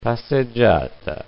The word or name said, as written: passeggiata